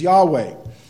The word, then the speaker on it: Yahweh: And you'll see it in your English Bible, capital L, small O-R-D. And then there's the other term, Yahweh. And Yahweh or Jehovah, depends on how you want to pronounce it, but Yahweh is probably the way it should be pronounced.